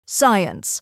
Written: science

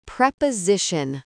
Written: preposition

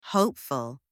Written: hopeful

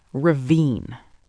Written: ravine

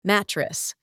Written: mattress

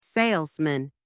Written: salesman

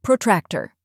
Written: protractor

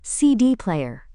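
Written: CD player

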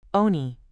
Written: oni

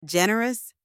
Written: generous

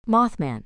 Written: mothman

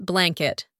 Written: blanket

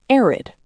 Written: arid

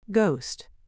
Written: ghost

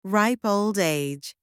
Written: ripe old age

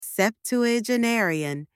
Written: septuagenarian

